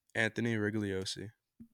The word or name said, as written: Anthony Rigogliosi